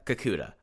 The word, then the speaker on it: Kakuta: And I'm stoked to see, like, we haven't really even, like, scratched the surface with someone like Madoka Kodoka. You know, Kikut. I got the name right when I was pronouncing it before. Madoka Kakuta.